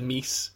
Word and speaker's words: meese: They said mouses, which I think they rhymed with houses, and then they said meese. – Which rhymes with cheese.